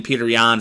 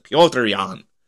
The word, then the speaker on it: Piotr: Man, was that Sugar Sean O'Malley and Peter Yan fight, or Piotr, I believe is how you correctly pronounce it. Piotr Yan.